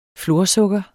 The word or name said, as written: florsukker